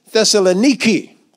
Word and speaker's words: Thessaloniki: Now say it like it's pronounced today. Thessaloniki.